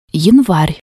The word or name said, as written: yanvar